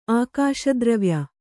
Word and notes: ಆಕಾಶದ್ರವ್ಯ: ākāśa dravya

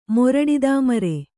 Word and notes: ಮೊರಡಿದಾಮರೆ: kannada; moraḍidāmare

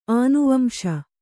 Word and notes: ಆನುವಂಶ: ānuvamśa

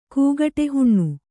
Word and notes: ಕೂಗಟೆಹುಣ್ಣು: kūgaṭe huṇṇu

